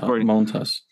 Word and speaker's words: Montas: Montas, right? That's how you're supposed to pronounce it. – Montas.